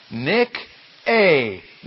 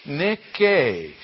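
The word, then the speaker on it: Nick-A (νίκη): And you're pronouncing it as we would in English. It's the word Nike. Nike, Nike, N, Nun, looks like a for you that are learning the alphabet, it looks like this, the Nun, the Yota, the Kappa, and the A sound, and it's accented there. And so, you know, that is pronounced Nick-A, Nick-A. And, of course, in English, we don't say Nikes. We call them Nikes.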